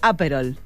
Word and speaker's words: Aperol: Y ponele que está muy de moda el Aperol. – Sí. – Algunos dicen Aperol. – Aperol, porque lleva tilde, sí.